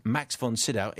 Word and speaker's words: Max von Sydow: By the way, Mark's pronunciation of the Swedes, Max von Sydow, is spot on.